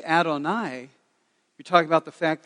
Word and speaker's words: Adonai: And that's how you say it, Adonai.